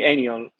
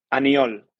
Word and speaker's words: Aniol: You can call me Aniol. – Okay. Let me hear you say your name. – Aniol.